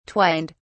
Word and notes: twined: British English

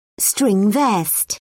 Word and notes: string vest: British English

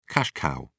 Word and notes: cash cow: British English

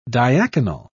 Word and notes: diaconal: British English

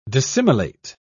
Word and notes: dissimilate: British English

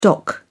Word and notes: dock: British English

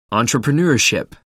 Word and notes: entrepreneurship: American English

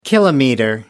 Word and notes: kilometer: British English

